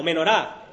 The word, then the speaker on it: menorá: No sé si alguna vez habéis visto una representación de lo que está aquí mencionado, esta menora o menorá, lo que es la pronunciación en hebreo.